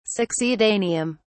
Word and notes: succedaneum: British English

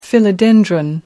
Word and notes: philodendron: British English

